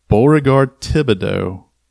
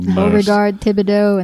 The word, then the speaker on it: Beauregard Thibodeau: And of course, they say his name like Foghorn Leghorn. It's like Beauregard Thibodeau. That's how they say it in the, in the trailer.